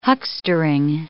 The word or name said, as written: huckstering